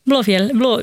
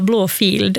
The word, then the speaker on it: Blåfjäll: Blåfjäll. Det som folk brukar tendera att uttala Blåfield, alltså blå, alltså en F-I-E-L-D. För att det är en gammal stavning av ordet fjäll. Så det namnet är Blåfjäll.